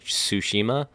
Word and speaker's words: Tsushima: It's called like the Ghost of Sushi. I don't even know how to pronounce it. It's like the Ghost of Sushi- Tsushima.